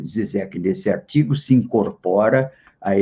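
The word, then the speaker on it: Žižek: escreve-se Zizek e se pronuncia Zizek,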